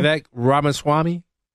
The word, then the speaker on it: Ramaswamy: Can you pronounce his last name? – Vivek Ramaswamy. – Yeah, very good.